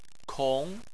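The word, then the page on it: kong: tone 2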